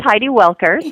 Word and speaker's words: Heidi Welker: No, I think, well, that's Heidi Welker, so yes. W-E-L-K-E-R, so we're good.